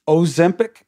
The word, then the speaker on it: Ozempic: Ozempic, am I saying it right? – Yeah.